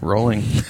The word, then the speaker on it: Rowling: That was Rowling. – Rolling. You have to know that, David. You have to know how to pronounce her name.